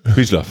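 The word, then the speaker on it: Wiesław: aber von Golek Wieslow, klingt das richtig? – Wieslow College? – Ja. – Oder nennen wir ihn Wieslow. – Wieslow, ja.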